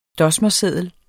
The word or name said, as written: dosmerseddel